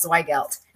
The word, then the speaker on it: Zweigelt: We are so excited to have you on the show, and I'm so excited to learn more about Zweigelt. Am I pronouncing that right? – Perfect. Yes.